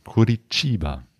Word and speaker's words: Curitiba: Ja, genau, mit so einem leichten Curitiba.